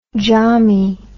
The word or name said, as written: jami